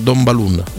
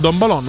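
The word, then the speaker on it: Don Balón: Posso correggerti, in inglese andrebbe bene, ma si chiama Don Balun. – Don Balun, non sono sicuro, ma insomma tutti dicono Don Balon, ma va bene, e mi fido della tua pronuncia.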